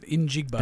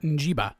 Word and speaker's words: Njigba: But Jackson Smith Njigba, which I had to learn how to pronounce it, Njigba, because I was pronouncing it Njiba.